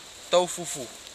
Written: taufufu

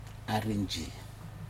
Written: arinji

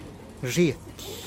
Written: zhietih

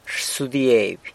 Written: rsudieby

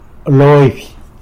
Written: Loby

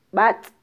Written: batz

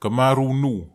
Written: kamaru nuw

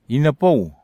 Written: inapau

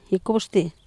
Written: gicubXtily

